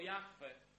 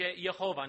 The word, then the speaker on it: Jahwe: Dodam może jeszcze, że gdy mówimy o naszym Bogu, o Jahwe, jest też niewłaściwy sposób wymawiania Jego imienia. Dzisiaj często mówi się Jehowa, nie wiem, czy słyszeliście takie. Jest to niewłaściwe, jest to błędne.